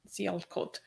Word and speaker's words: Sialkot: So on the day of the murder, December 3rd, he said, the horrific vigilante attack in Sialkot, is that how you say it? – Sialkot.